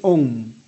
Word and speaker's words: Aum: Pronuncia-se Aum. Para algumas culturas, a pronúncia de Aum deve ser sempre Om.